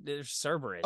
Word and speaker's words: Cerberus: But the problem is Cerebus, I don't think it's pronounced Cerebus. – Yeah, Cerebus is the aardvark. You're Cerberus.